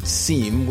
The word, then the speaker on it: Cime: Well, I wanted to pronounce it Cime, which means mountain peak in French.